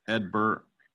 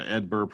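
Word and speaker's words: Hedbert: and that is outfielder Edbert Perez. Now, he's got a silent H and a silent T to begin and end his first name, which is kind of interesting. But, yeah, Edbert Perez.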